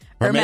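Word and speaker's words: Hermès: I'm going to say it wrong, but it's Hermes, right? – Hermes.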